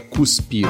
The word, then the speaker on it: cuspir: É gospe, não é deguspir ou é cuspir? – Cuspir se escreve com C, mas tradicionalmente as pessoas falam com G, né?